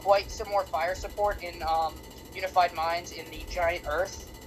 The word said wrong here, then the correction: Hearth: or whatever, however you pronounce it. – Hearth, I believe.